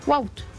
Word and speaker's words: Wout: No, no está bien, se dice Wout. – Que yo le voy a decir Wout Van Aert. – Pero es que no se dice Wout, se dice Wout, es muy diferente. Él mismo pronuncia su nombre, Wout, se llama Wout.